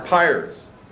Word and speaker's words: pyre: How do you pronounce the word P-Y-R-E? – Pyres. – There were pyre? – Yeah. – Three pyres. I don't know if some of you, it's pier or pyre, right? There are three pyres on the altar, and these pyres had to have fire burning on them on a constant basis.